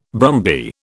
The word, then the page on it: brumby: British English